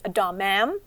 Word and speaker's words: damam: The pronunciation is damam.